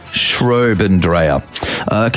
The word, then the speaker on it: Schraubendreher: The pronunciation originally is Schraubendreher. Schraubendreher.